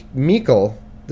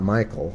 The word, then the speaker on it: Michal: And Michal, this is a girl, so I'm guessing it's not Michael. It's spelled M-I-C-H-A-L. We'll say Michal. I'll just call her, it's almost like Michelle, but Michal.